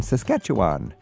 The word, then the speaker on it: Saskatchewan: Christy's on the phone from Martinsville in Saskatchewan.